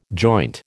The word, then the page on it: joint: American English